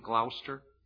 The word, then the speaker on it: Gloucester: Is that the correct pronunciation? Gloucester? – Gloucester.